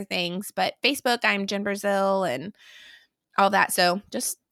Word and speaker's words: Brazeal: And it's Brazeal, pronounced like the country, but spelled Brazeal.